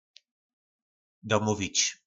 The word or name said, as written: domovic